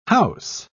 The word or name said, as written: house